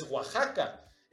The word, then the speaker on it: Oaxaca: Pero los mexicanos, pues nos quedamos con la grafía X y con el sonido, perdón, con el sonido de J, pero con la escritura del sh. Entonces, es por eso que ahora en México pasan cosas como la colonia Joco y se escribe Xoco, o Xalapa y se escribe Xalapa, ¿no? Entonces el Xalapa o el Oaxaca, pues es Oaxaca.